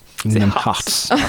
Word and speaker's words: Kingdom Hearts: Kingdom Hearts.